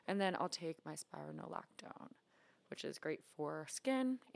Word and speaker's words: spironolactone: And then I take spironolactone.